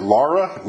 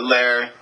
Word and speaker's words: Lerre: So it's spelled L-E-R-R-E. How do you pronounce that? Is it Lara? Lara? How do you pronounce that? – Lair. Silent Lair.